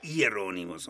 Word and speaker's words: Hieronymus: Hieronymus. – With Hieronymus. Hieronymus. Hieronymus. Anyway. – The Latins didn't drop their H's. – No. And of course, they weren't as hard as we say. Hieronymus, almost.